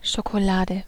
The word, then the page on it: chocolade: /ˌʃo.ko.ˈla.də/